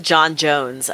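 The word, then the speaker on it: J'onn J'onzz: And when they said John Jones, I was like, that is such a, like... – Yeah, no, that is, it's always pronounced John Jones, and it is spelled that weird way.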